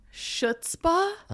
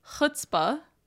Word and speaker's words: chutzpah: Well, how do you say it? – Chutzpah. – There's no C. – Chutzpah. There is a C, but it's chutzpah.